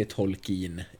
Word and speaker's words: Tolkien: Eh, jag kommer säga Tolkien framöver, men jag har förstått att det korrekta uttalet är Tolkien egentligen.